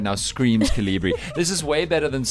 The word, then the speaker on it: Calibri: How do you say it? Is it Calibri? – Calibri.